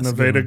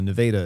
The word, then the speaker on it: Nevada: For all of you people out there, Nevada is what we call Nevada in Iowa.